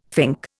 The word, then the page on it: fink: Indian